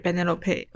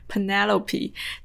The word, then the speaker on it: Penelope: 演的，然后这两位女演员就是在里面担当的主配角的角色，一个是母亲 Yanis，就是 Benelope。我感觉我用西班牙的这个发音发这个名字就有点奇怪，我还是用英文英文发音发吧。 Penelope，她在这里面演的就是这一个主要的母亲 Yanis。